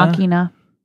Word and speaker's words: Ex Machina: Ex Machina. – Ex Machina. – I always used, when it came out, I was like, so Ex weird. Ex Machina. Let's go see Ex Machina.